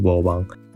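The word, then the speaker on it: 無望: 那这首歌其实是一张呃，其实是一首台语歌，所以应该念成《I Love You》无望。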